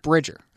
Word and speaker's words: Bridger: Was I saying it wrong? – What? – Jim Bridger. – Bridger. – Yeah.